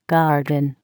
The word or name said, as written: garden